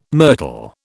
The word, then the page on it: myrtle: British English